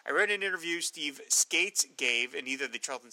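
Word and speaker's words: Skeates: and he confirmed that it's pronounced Skates.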